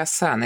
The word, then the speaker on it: асана: позу знаю я асана. Это к тому, что ударение на второй слог.